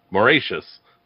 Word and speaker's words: Mauritius: Megan, which flightless bird made a particularly tasty snack for Dutch sailors on the island of Mauritius. Sorry. I even phonetically wrote it, and I still said it wrong. Mauritius.